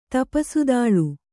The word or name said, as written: ತಪಸುದಾಳ್